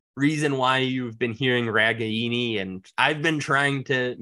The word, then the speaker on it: Ragaini: So if you look at the Iowa media guide, it says Reganey. That's been the official pronunciation that Iowa has provided these last few years. We found out this year that the Italian pronunciation, the proper Italian pronunciation, is Ragaini.